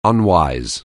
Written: unwise

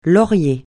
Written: laurier

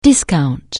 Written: discount

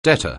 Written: debtor

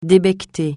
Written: débecter

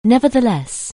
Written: nevertheless

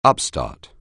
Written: upstart

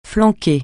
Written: flanquer

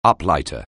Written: uplighter

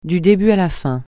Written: du début à la fin

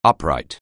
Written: upright

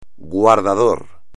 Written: guardador

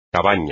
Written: cabaña

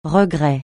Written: regret

